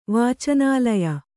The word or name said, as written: ವಾಚನಾಲಯ